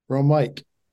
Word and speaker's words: Romeike: Romeike.